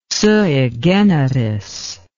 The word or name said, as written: sui generis